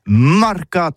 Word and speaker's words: mercat: Eh bien, disons à tous, mais le mercat de l'Uni ou le mercat des rebelles, mais en gascogne toulousano, c'est prononcé sur les marcat, avec un A marquette. En gascogne toulousaine, bah, du côté, par exemple, de l'île des Bâches, c'est l'île Jourdain, pour parler du marché, vous prononcez les marcat.